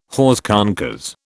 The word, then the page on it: hors concours: British English